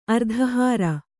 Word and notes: ಅರ್ಧಹಾರ: ardhahāra

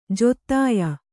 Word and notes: ಜೊತ್ತಾಯ: jottāya

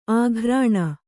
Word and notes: ಆಘ್ರಾಣ: āghrāṇa

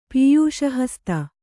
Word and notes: ಪೀಯೂಷಹಸ್ತ: kannada

